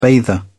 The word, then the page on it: bather: British English